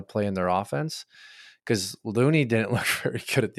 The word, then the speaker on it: Looney: Is it Looney? Am I saying that properly? – It's Looney is how you say Looney.